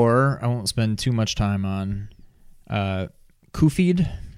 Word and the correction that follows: Cuphead: I think is how you pronounce it. Uh, oh, sorry, Cuphead. Cuphead. um, Cuphead.